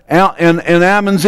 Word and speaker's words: almonds: You don't pronounce the L in almonds either.